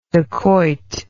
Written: dacoit

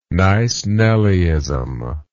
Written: nice nellyism

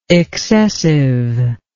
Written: excessiveness

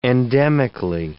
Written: endemically

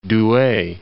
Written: Douai

